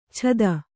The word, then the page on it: ಛದ: Kannada; chada